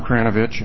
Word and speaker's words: Kranovich: Madam Chairman, members of the committee, you did indeed pronounce my name correctly. It's phonetic, Tom Kranovich, and I'm surprised how many people, when they get to three syllables in a last name, just their brain stops.